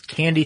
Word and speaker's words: Kandi: it's Candy Technologies, spelled K-A-N-D-I, but pronounced candy, mm-hmm.